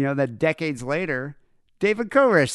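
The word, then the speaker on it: Koresh (כורש): And Cyrus in Hebrew is pronounced Koresh.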